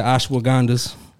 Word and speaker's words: ashwagandha: You know, I like the ashwagandhas.